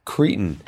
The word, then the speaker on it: cretin: People who don't understand that that's an insult because it's supposed to be pronounced cretin.